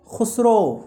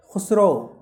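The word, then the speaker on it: Khosrove: खुसरो spelling है दूसरे page में दूसरी लाइन में के H O S R O V E खुसरो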